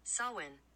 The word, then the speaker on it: Samhain: Happy Samhway. Oh, God, I feel so bad. I always say it wrong. Okay, so wait. – Samhain. – Samhain. Great. Did you guys hear that? I had to truly go on Google and ask how to pronounce it.